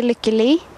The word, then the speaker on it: Lykke: I say Lykke.